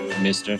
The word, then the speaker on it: Meister: Say it's in German. Meister. How do you say it in German? – Mister. – But it's spelled Meister. M-Y-S-T-E-R.